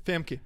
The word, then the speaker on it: Famke: Oh, Famke Janssen's hot. – Yeah. – Say it again. Famke? – Yeah, she is. – Famke. – How do you spell that? – F-A-M-K-E. – That's how it sounds.